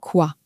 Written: quoi

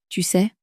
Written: tu sais

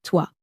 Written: toi

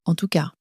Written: en tout cas

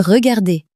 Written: regarder

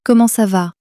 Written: comment ca va

